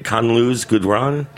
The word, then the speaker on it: Khalil Gibran: Khalil Gibran? – Yeah.